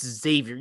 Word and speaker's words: Xavier: It's Xavier. You don't pronounce the X in Xavier.